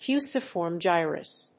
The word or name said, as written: Fusiform gyrus